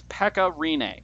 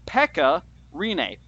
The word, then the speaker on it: Pekka Rinne: His name is Pekka Rinne. Pekka Rinne.